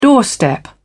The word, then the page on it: doorstep: British English